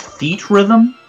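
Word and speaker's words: Theatrhythm: I believe it's pronounced Theat Rhythm.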